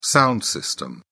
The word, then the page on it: sound system: British English